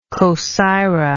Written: cosyra

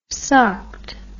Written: socked

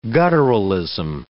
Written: gutturalism

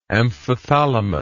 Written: amphithalamus

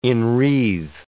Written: enwreathe

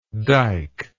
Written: diker

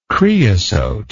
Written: creosote

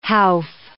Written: howff

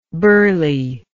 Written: berley

